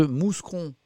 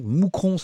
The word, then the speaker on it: Mouscron: Euh, qui m'a permis notamment de corriger ma prononciation de mouscron. Donc, moucron, Samuel Etienne.